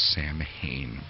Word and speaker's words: Samhain: Samhain in English actually looks like the word Samhain, and some people mistakenly pronounce the word as Samhain, but if you see the word Samhain, it's actually pronounced Samhain, pronounced more like S-O-W-A-N.